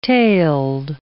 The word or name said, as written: tailed